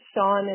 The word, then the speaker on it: Sian: Hi, my name is Sean, and it's spelled like Sian.